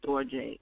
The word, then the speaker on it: storge: And then storge, S-T-O-R-G-E, pronounced storge. That is family love.